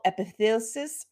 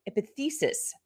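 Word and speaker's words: epiphysis: Um, anyway, uh, there are about 20 plus codes de- uh, describing slipped femoral epithesis. Oh, I didn't say that right. Epithesis.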